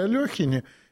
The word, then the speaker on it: Алехин: Так вот, насколько я знаю, его фамилию действительно в разное время его жизни произносили по-разному. Причем он сам, насколько я наслышан, предпочитал, чтобы его именовали именно Алехин. В любом случае, оба варианта произношения допустимы.